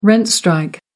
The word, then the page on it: rent strike: American English